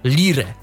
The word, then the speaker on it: Lire: więc ja może przeliteruję. Nazwa tej aplikacji to Lire. L-I-R-E.